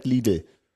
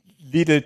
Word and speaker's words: Lidl: Nee, ich habe als Stichpunkt den Namen Lidl Trek drin, weil der im Englischen als Little Trek ausgesprochen wird.